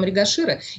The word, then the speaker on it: Мригашира: Следующая наша накшатра – это пятая накшатра, и называется она Мригашира. Правильно ли я ударение ставлю? – Да, совершенно верно. Называется она Мригашира.